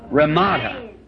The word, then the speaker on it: Ramada: Ramada. If I say that right? – Ramada.